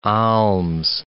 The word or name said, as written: alms